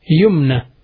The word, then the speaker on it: Yumna: Yumna. It's Ya, Mim, Noon, Ya Maqsura, which is pronounced as an alif.